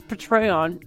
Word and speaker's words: Patreon: Patreon, which we pronounce Patreon because that's how my mom says it.